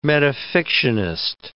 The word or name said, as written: metafictionist